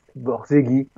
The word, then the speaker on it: Borzage: Et un cinéaste du muet, donc j'ai dit deux mots tout à l'heure, qui s'appelle Frank Borzage, Borzegui dans la vraie prononciation,